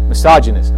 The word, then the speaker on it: misogynist: But the culture today says this is real life, that we would never be denied our every sensual desire, no matter how misogynic, how sexist or demeaning. Misogynist. I knew I'd get it right. I always put the emphasis on the wrong syllable in that.